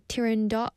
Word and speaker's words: Turandot: Firstly, I'm going to talk about Turandot, the opera. We talked about this on Culture Walk. Turandot was just in Korea recently in the summer, in July, and Turandot, or the Italian pronunciation is Turandot, yeah, is an opera in three acts by Puccini.